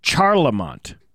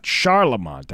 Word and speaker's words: Charlemont: It's pronounced Charlemont, not Charlemont, as I said.